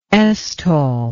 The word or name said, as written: stol